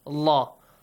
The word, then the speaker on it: Allah: The letter lam in the word Allah is pronounced with a heavy sound. Allah. Allah.